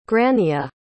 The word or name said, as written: grania